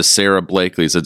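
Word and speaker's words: Sara Blakely: You see them start to move and morph into the Richard Bransons, the Steve Jobs, the Sarah Blakelys.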